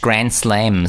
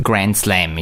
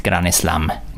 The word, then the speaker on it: Grand Slam: Grand Slam ist angeschrieben, gewesen, aber die Spanier können das äh, nicht so richtig aussprechen. Granes, und dann hat es immer so tönt Granes Slam. Granes Slam. – Genau. Das ertönt äh, äh, grande. – grande. grande Slam.